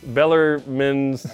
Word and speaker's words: Bellarmine: He is Bellarmine. – Take the E off so we know it's Bellarmine.